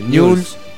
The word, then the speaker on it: Newell's: ¿Viste cómo se dice Newell? Siempre es complicado. Newell's. Newell's.